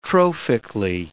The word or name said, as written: trophically